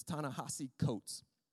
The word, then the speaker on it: Ta-Nehisi Coates: It's Ta-Nehisi Coates.